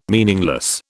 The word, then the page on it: meaningless: British English